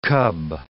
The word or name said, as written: cub